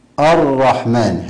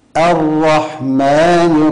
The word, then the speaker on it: الرحمن: فالقارئ فخم الهمزة مع الراء، الرحمن، هكذا خطأ. الرحمن، هذا النطق خطأ. الصواب ترقيق الهمزة، لأن الهمزة حرف مرقق. هكذا الرحمن الرحيم، الرحمن الرحيم، دون مبالغة.